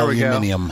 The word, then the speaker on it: aluminium: I don't see that last I in there, but there aluminium. – We go. – Aluminium. – What? – Aluminium is how they say it.